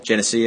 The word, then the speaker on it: Genesee: Genesee? – Yeah.